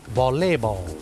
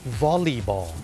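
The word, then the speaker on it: volleyball: วอลเลย์บอลแต่จริงๆแล้วนะครับเจ้าของภาษาเนี่ยไม่ได้ออกเสียงว่าวอลเลย์บอลนะครับเขาออกเสียงว่าวอลลีบอลวอลลีบอลตัว L E Y เป็นออกเสียงลี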